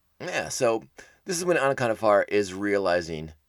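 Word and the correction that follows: Onaconda Farr: Anaconda Far, jeez. Pronounce the O a little bit harder. Good lord.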